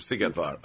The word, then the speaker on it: Szigetvár: The Siege of Szigetvár.